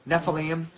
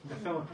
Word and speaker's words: Nephilim: Nephilim. – Oh, Nephilim, okay.